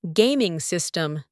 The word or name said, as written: gaming system